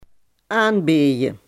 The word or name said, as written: aanbieden